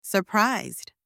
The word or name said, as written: surprised